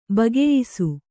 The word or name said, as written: ಬಗೆಯಿಸು